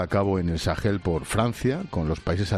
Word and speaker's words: Sahel: Ya que tienes el mapa, el inmenso Sahel, pronunciado con H gutural, es la zona del desierto del Sáhara al norte y de la sabana sudanesa al sur.